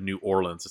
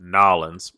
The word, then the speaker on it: New Orleans: And yeah, I can't believe you said New Orleans. It's pronounced Nollins. But anyway, it's in Nollins this year.